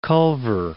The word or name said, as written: Culver City